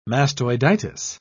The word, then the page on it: mastoiditis: British English